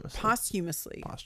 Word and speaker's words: posthumously: Is it posthumously? – I like posthumously. That sounds very Jersey to say it that way. – Did I say it wrong? I'm pronunciations are not my thing, apparently. – Posthumously. – Thank you.